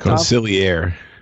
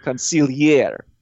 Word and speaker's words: consigliere: If I'm Dave Clawson's consigliere, if I'm sitting next to how do I pronounce it? – Consigliere. – Consigliere.